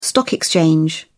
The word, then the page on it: stock exchange: British English